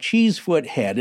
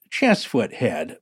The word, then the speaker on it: Cheesefoot Head: I feel it would be remiss of me not to point out that Cheesefoot Head is actually pronounced Chessfoot Head by true locals.